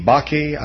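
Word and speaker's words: Bakken: The I don't know if I pronounce this correctly, B-A-K-E, Bakke, I suppose, is the correct pronunciation.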